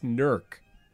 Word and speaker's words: Newark: They said, take the W out. It's Newark. Like, that's how you say it if you're from there.